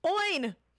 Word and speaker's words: Eoghan: You know how they pronounce, you know how they spell Ian? – No. – It's E-O-G-H-A-N. – Oin.